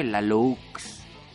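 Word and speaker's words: Laloux: Ah, se escribe Lalox. Y según yo se pronuncia Lalo.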